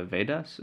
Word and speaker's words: Vedas: So you put down that you wanted to talk about a little bit about the Vedas.